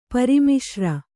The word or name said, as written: ಪರಿಮಿಶ್ರ